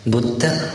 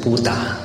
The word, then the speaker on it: Buddha: 我们从它的词源来讲，佛教巴利的 Buddha Sasana， Buddha 是佛陀，不要读成 Buddha，那个是错误的读，因为我们不能够，我们应该按巴利来读，而不应该按照英文来读，是 Buddha 或者是 Buddha 都可以，这是指佛陀。